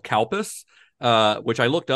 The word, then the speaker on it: Calpis: C-A-L-P-I-S. When I wrote it down, I was like, the way that this is going to sound when an American pronounces it is that it's going to sound like cow piss. But it's cow piss. It looks like milk. – Kalpis, C-A-L-P-I-S.